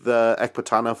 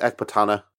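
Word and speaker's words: Ecbatana: See, I pronounce it Ekbatana, because I think that the emphasis is on the T, Ekbatana.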